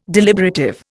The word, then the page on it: deliberative: Indian